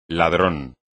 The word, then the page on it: ladrón: Spanish